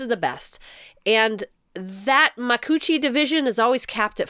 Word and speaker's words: Makuuchi: Makuuchi.